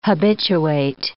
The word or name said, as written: habituate